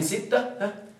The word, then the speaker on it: ستة: مخمس، من ستة سداس مسدس. هناك تنبيه، العدد ستة ننطقه بالتاء لكن أصله بالدال، أصله